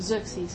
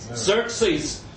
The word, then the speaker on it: Xerxes: Xerxes. – Xerxes.